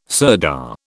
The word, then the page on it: sirdar: British English